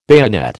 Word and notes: bayonet: American English

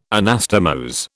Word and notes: Anastomose: British English

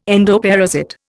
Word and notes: endoparasite: Indian

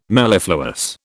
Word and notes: mellifluous: British English

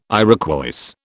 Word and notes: Iroquois: British English

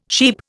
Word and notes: sheep: Indian

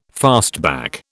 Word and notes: fastback: British English

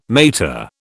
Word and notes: mater: British English

